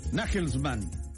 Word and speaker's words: Nagelsmann: Nagelsmann, así se pronuncia, ¿pero? – Sí, o Nagelsmann.